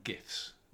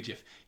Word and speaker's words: GIF: If you use an acronym, acronyms take the attributes of the first word. The first word being graphical has a hard G. If it was German interchange format, it would be a GIF. It's graphical interchange format, so it's a GIF.